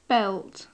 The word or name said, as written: belt